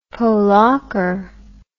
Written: polacre

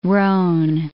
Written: roan

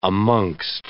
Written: amongst

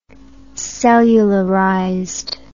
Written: cellularized

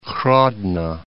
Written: Hrodno

